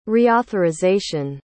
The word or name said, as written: reauthorization